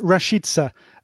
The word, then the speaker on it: Rashica: I think I just about got the pronunciation right there. Rashica we're going with, aren't we? That's what your expertise has told us. – Yeah, Milot Rashica.